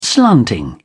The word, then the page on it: slanting: British English